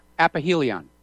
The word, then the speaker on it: aphelion: Aphelion. – Oh, is that how you pronounce it? – Yeah. – It has an F here, but aphelion or aphophilia.